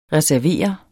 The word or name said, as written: reserveret